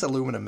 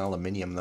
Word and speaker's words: aluminum: I guess aluminum makes more sense than aluminium, though. That's kind of... – Yeah, I mean, you're adding syllables for no reason.